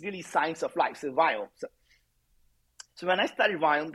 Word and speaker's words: Viome: So most people who may not know, I started a healthcare company called Viome. And as an Indian guy, I cannot pronounce the word V. So it's V as in Victor.